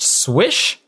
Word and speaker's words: SWSH: It's written SWSH, pronounced Swish, or Pokemon S and more different S.